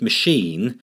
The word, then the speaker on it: machine: When reading the word machine, the CH is pronounced SH, as in chef, in row C3 in sound box 17, and not K, as in school, in sound box 2, or CH, as in chair, in sound box 3.